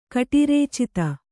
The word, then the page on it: ಕಟಿರೇಚಿತ: kannada